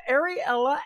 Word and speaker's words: Ariella: Ariella, is that right? – Yes. Yes, okay. It is. – All right. Just like the Disney Princess Ariel with an A on the end of it.